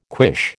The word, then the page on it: quiche: American English